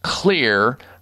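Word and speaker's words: Xlear: And that is Clear Rescue Nasal Spray. Now, Clear is spelled with an X, X-L-E-A-R.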